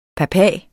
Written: papa

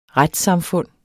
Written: retssamfund

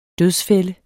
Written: dødsfælde

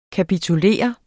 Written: kapitulere

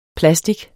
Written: PLASTICEN

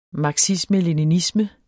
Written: marxisme-leninisme

